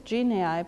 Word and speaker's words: genii: So you pronounce it I pronounced it genii because it's the plural of genius.